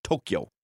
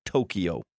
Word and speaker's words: Tokyo: Somebody says Tokyo is two syllables. Tokyo. Not Tokyo.